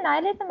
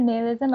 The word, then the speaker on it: nihilism: They say nihilism or nihilism. I've heard people say both.